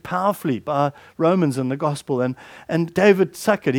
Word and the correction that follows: Suchet: he's an actor. I don't know, is that how you pronounce his name? Suchet, sorry. David Suchet.